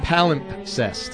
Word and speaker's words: palimpsest: P-A-L-I-M-P-S-E-S-T. Palimpsest. – Palimpsest. – Yes. – And that is the correct pronunciation? – Yes, it is.